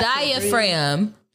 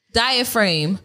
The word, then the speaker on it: diaphragm: your diaphragm. – Diaphragm. – You said it right the first time.